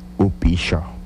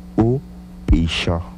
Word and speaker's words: upisho: Repito nuevamente entonces, la palabra que acabamos de escribir y pronunciar es despertarse, que en lengua yocotán se pronuncia upisho. Repito nuevamente, upisho.